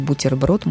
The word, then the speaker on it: бутерброд: Лена спрашивает, а что в слове «бутерброд» буква «т» уже должна произноситься мягко? Вообще-то у нас уже есть слово «бутер». Да, конечно, это разговорный русский, но он не «бутер», он именно «бутер». А вот это сокращение от «бутерброда», но все совершенно естественно, что «бутерброд» может произноситься мягко, потому что «е», которое стоит после «т», требует вообще-то смягчения.